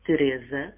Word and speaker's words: Teresa: Many webheads call me just Ter or Terry, but actually my name in Portuguese is Teresa. And just like Mariana was telling me yesterday, Mariana from Croatia, that my name is pronounced practically the same way in Croatian, which is interesting.